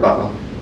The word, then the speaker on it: Buffa: My name's Albert Buffa. – Thank you, sir. – My last name is spelled B-U-F-F-A.